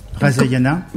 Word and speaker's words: Rasayana: Razayana. – Comment vous écrivez Razayana? – R-A-S-A-Y-A-N-A.